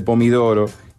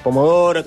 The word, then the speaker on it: pomodoro: De ahí viene que mi abuela decía: voy a llevar una lata de pomidoro, pero no sé si hubo una marca de extracto de tomate que se llamaba así. – Sí, o capaz que, bueno, tal vez pronunciación nomás, pero sí, es pomodoro, también, bueno, pomarola como la salsa y distintas variantes de ese tomate que ya no es amarillo.